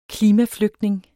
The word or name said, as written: klimaflygtning